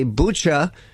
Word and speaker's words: Bucha: But when you pronounce it in English, you say Bucha, it sounds like butcher.